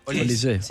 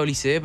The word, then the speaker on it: Olise: Olise. Él lo pronuncia Olise, pero creo que se pronuncia Olise.